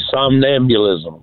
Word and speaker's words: somnambulism: The somnambulism question.